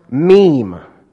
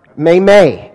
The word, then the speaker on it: meme: The important thing, number one, is that it's pronounced meme, not meme, not may-may. It is meme.